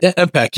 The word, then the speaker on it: Empeq: So what is MPEC? Am I pronouncing it correct? – MPEC, yes. – MPEC. Yes, E-M-P-E-Q.